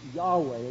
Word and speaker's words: Yahweh: God has revealed his personal name to his people, and his personal name is pronounced something like Yahweh, as it's written here with small capitals, Lord.